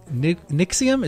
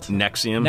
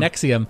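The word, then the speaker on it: NXIVM: says, it's like a sexier Nixium. Is that how you actually pronounce that? The real cult? – Nexium. – Nexium.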